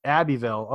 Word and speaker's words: Abbeville: Am I saying that right? Abbeville. – Abbeville. Okay. Yep.